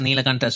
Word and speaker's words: Nilakanta: Nilakanta Srinivasan.